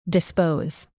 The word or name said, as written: dispose